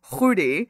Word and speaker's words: Khouri: My last name is Khouri, and it's Syrian. And in Syria, in Arabic, you pronounce it Khouri.